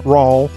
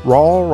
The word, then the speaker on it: Rawl: I am John Rawl. Rawl rhymes with y'all, by the way.